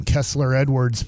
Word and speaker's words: Kessler Edwards: Some dude named Kessler Edwards played 9 worthless minutes.